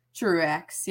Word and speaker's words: Truax: It's either not like a French pronunciation or nope, just regular old Truax.